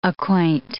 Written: acquaint

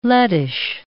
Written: Lettish

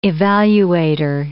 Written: evaluator

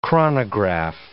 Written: chronograph

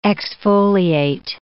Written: exfoliate

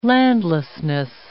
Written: landlessness

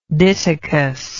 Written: ditokous

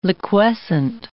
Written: liquescent

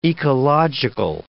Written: ecological

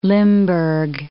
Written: Limburg

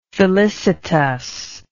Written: felicitousness

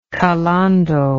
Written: calando

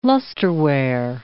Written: lusterware

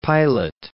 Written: Pilate

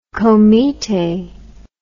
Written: komiteh

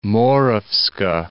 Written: Moravska Ostrava

